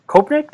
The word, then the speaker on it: Kopnak: So first joining us is going to be Matt Kopnak.